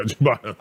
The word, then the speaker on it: ciabatta: Yeah, ciabatta.